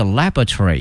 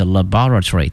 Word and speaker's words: laboratory: The OSCAR 哈，那么它有一个缩写啊，叫 OSCAR Building will provide 20,000 square meters， 20,000平米哈，这个平米叫 square meter， a high quality 高品质的 research laboratory 哈，那么实验室呢有两个发音，一个是 laboratory，一个是 laboratory 都可以。